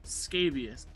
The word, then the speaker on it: scabious: Scabious, a plant of the teasel family with pink, white, or most commonly blue pincushion shaped flowers. Let me make sure I'm pronouncing this right. – Scabious. – Yes, scabious.